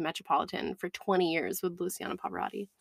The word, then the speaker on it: Luciano Pavarotti: And we listened to Luciano Pavarotti.